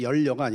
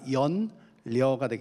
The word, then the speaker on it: 연려: 이렇게 연려문 할 때 연려가 아니라 연려가 되겠죠. 발음이 좀 어려운데 고우면서도 아름답다라는 우리 옛 말이죠.